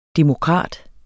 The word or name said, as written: demokrat